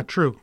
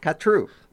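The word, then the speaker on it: Khatru: It was written by keyboard genius Rick Wakeman, singer John Anderson, and guitarist Steve Howe, and the song is called Siberian Katru. Did I pronounce that right? – Katru. – Katru.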